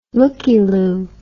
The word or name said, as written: lookie-lou